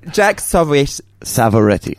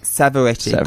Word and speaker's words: Savoretti: Did I say it right? – Yeah, you did. Close. It's a tough one. – Jack Savoretti. – Savoretti. – Got it. – Yeah. People mix it up with Pavarotti.